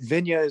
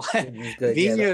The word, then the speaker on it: Vigna: That's probably the correct pronunciation, but Vigna is good as well. Vigna is probably the actual, pronunciation for sure.